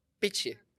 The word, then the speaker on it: pici: No jasně, protože ty to vyslovuješ, že jo, piči. Abych to vyslovilo správně, abych psali právě piči, že jo.